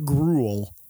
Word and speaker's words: gruel: To the future gruel. – I think gruel is actually two syllables masquerading as one.